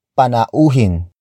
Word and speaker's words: panauhin: The next word is panauhin. Panauhin. Panauhin. Panauhin is spelled as P-A-N-A-U-H-I-N.